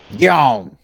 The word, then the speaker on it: Gihon: So we got a road in here in the area that I live in called, well, it's spelled G-I-H-O-N. What would y'all call that? – Gone. – Perfect.